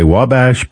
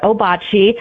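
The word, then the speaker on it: Ouabache: So you've answered a question already, because the how you pronounce Obachi. Some people say Wabash, but it's Obachi, right? – Mm-hmm. It's the DNR. We pronounce it Obachi. But if you speak French and read French, that word is actually Wabash.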